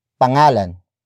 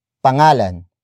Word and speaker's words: pangalan: And then we add the ng linker to connect it to its corresponding noun, which is pangalan. Pangalan.